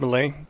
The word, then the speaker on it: Milei: Milei, is it pronounced Milei? – Milei.